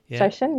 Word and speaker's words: Shoshin: Is it Shoshin? Is that how you say it? – Yeah. Shoshin, yeah.